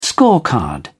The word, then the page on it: scorecard: British English